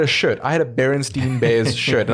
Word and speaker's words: Berenstain: but his name has always been Berenstain, pronounced stain, as in coffee stain, and he has been confused and pronounced Berenstain over the years, especially when he was younger.